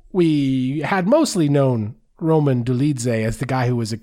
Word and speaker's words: Dolidze: Roman Dolidze. Did I say that right? How do you say it, Ben? – Dolidze? – Dolidze. Roman Dolidze went out there and put a citizen's arrest on Jack Hermanson.